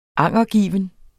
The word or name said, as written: angergiven